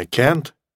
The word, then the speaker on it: can't: То же самое can't, при этом же написании, можно еще произносить как can't, can't. Долгое А, ребятки, обязательно долгое А,